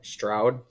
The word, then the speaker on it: Stroud: Yeah, and so is Stroud. Is that how you say his name? Yeah.